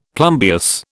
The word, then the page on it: plumbeous: British English